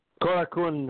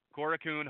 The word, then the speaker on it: Korakuen: Karaki Ko Korakian Hall in Tokyo. – Korakuen Hall. Thank you. Korakuen Hall in uh, Tokyo in February the 22nd through the 23rd.